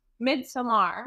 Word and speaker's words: Midsommar: You said it the way it's supposed to be pronounced, Midsommar.